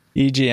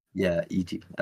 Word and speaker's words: Eiji: In a new Game Informer interview, series producer, is it Eiji? – Eiji Aonuma. – Eiji. That's what I thought. I wasn't sure how to pronounce it.